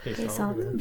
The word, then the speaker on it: basalt: Oh, so the color, if we're going to do the metal or the, you know, whatever, stone, black could either be, I think it's pronounced basalt or basalt. – Basalt.